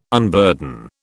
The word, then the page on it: unburden: British English